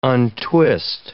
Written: untwist

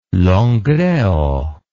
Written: Langreo

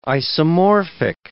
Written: isomorphic

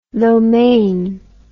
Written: lo mein

